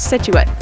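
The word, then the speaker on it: Scituate: And thank you to George for teaching me how to pronounce Situate.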